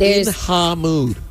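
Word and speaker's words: In Ha Mood: Ice Spice is being dragged to court over her song "In Hamoud" because it's pronounced "In Hamoud."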